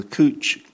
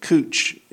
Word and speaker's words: Cwtch: And perhaps I should say that Cooch is spelt C-W-T-C-H, so I'm assuming the W is a Welsh W.